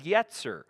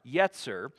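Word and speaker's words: yetzer (יצר): The first word, the first Hebrew word is yetzer. It's spelled Y-E-S-E-R, yetzer.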